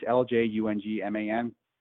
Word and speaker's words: Ljungman: Then there's a Swedish center, Daniel Lund, I think he's pronounced it Lungman. It's Ljungman.